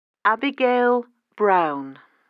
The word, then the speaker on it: Abigail Brown: Abigail Brown.